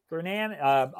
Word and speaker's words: Kernahan: And I'm speaking with Paolo Kernahan. I knew I was going to butcher your last name. I apologize. – At least one. Kernahan.